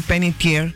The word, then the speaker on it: Panettiere: Bueno, hay una noticia que entristece al mundo de Hollywood, el fallecimiento de la actriz Hayden Panettiere. Se pronuncia así como si fuese en francés. Su apellido tiene un origen italiano adaptado a la fonética estadounidense y la pronunciación es así, de esa forma.